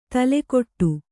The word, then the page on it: ತುಲಾಕೋಟಿ: Kannada